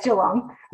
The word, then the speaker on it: Geelong: I love how you say Geelong. – Is it wrong? Am I saying it wrong? – It's just Geelong. – Geelong. Excuse me.